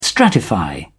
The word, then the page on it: stratify: British English